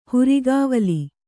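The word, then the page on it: ಹುರಿಗಾವಲಿ: hurigāvali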